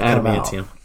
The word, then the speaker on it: adamantium: there's three sharp blades of antman- steel. I think I said that right. – Mm-hmm. – that that adamantium.